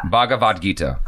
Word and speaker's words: Bhagavad Gita: Bhagavad Gita.